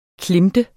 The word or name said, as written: klemte